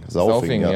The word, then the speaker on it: Sauvignon: Wie spricht man das aus? Sauvignon? – Sauvignon, ja.